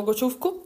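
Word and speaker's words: Ugochukwu: Allora, secondo me sono Gociufku, che ho imparato a pronunciare perché ho chiesto ai ragazzi della Prema quando sono andata alla all'ACI Weekend e loro mi hanno detto che si pronuncia così.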